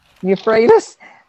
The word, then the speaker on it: Euphrates: the river how do you pronounce that? – Euphrates, the Kenites, the Kenizzites, the Ken...